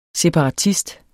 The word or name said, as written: separatist